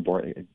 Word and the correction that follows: Boebert: how did I pronounce her last name? – Boebert. – Boebert.